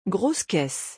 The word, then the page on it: grosse caisse: French